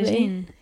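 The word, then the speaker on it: Medellín: I went to like Medellin. Mm-hmm. I don't know if I ever pronounced that properly. – Medellin, yes.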